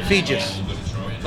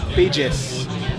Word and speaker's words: Feges: Feges. Like Regis. Feges. – Okay. – I want to make it Rhymes with Regis.